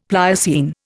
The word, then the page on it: Pliocene: Indian